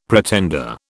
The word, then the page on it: pretender: British English